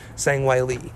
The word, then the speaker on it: Zhang Weili: Wiley's, proper pronunciation is Zhang Weili. Zhang Weili is going to be taking on Joanna,